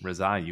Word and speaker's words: Rezaei: Rezaei, you got it, man.